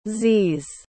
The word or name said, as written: zees